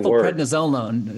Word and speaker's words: methylprednisolone: about trying to pronounce that word, methylprednisolone one long methylprednisolone. That's how I say it.